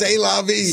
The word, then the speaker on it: C'est la vie: C'est la vie.